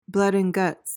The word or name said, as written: blood-and-guts